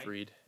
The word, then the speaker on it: Fried: Hyunjin Ryu is going to be starting against Max Fried. I don't know how to pronounce that. Fried. – It's just Fried.